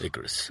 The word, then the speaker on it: Icarus: Is it pronounced Icarus or Icarus? – Icarus.